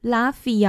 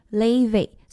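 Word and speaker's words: Laufey: Levy 的名字呢其实很常被英文的发音念成 Laffy 啊，或者是 Levy，那其实呢是 Levy。他这个 Levy 的名字呢是来自于冰岛的语，然后呢正确发音比较接近是 Levy，那其实他有一个中文名字叫做林冰，那因此呢也有不少的亚洲的粉丝呢会叫他冰冰这样子。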